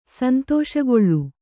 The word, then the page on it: ಸಂತೋಷಗೊಳ್ಳು: Kannada